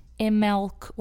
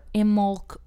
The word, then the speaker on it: Imbolc: And right now is the perfect time to do it, because at the beginning of February, we also have the pagan holy day of Imbolc, sometimes pronounced Imelc or Imolc or Imolch.